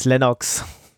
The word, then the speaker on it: Linux: I pronounce Linux as Linux.